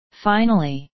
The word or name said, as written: finally